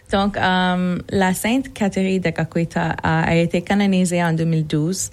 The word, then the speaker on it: Kateri Tekakwitha: À Caughnawake, on va pouvoir aussi découvrir le sanctuaire qui va être dédié à Kateri Tekakwitha.